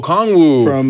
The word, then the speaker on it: Okongwu: Oyeka Okongwa. – Oh, Okongwu. For the Hawks.